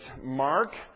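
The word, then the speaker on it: Marc: My name is Mark.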